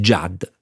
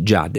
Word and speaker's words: Judt: Judd, si scrive J-U-D-T, il suo cognome e la pronuncia semplicissima, non semplicissima, la semplificherò in Tony Judd.